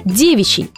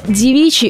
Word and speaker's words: девичий: Итак, правильно все же будет ставить акцент на первый слог, то есть девичий.